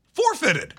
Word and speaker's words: forfeited: Forfeited. – No. Forfeited. – You are putting the emphasis on the four and not the fitted.